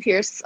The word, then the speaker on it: Peirce: You can find me on the internet and the interwebs at Lizzie Peirce on Instagram and on YouTube and on Twitter.